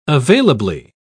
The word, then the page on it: availably: British English